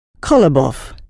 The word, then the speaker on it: Kolobov: Kolobov. – Can you spell it? – K-O-L-O-B-O-V.